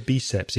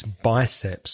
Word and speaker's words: biceps: Also, be careful with the pronunciation of biceps, Gabby. I think you said biceps. It's biceps. So your biceps and your triceps.